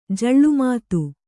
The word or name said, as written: ಜಳ್ಳುಮಾತು